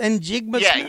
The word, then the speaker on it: Njigba: Njigba? – Yeah.